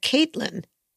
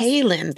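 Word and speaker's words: Cailin: I thought, because I heard Caitlin, but not yes. Kaylin that's spelled that way.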